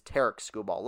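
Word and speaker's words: Tarik Skubal: It is Tarek Skubal, like Derek, but with a T.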